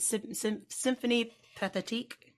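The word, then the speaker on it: Symphonie Pathétique: Is it Symphonie Pathetique? Mm-hmm. Is that how you say it? – It's, yeah.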